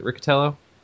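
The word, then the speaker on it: Riccitiello: Am I pronouncing his last name right? Is it Riccatello? – Yes, you're pronouncing it.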